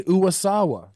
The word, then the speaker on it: Uwasawa: Uwasawa? – Yeah. – Uwasawa? Is that how you say it? – Nayuki Uwasawa.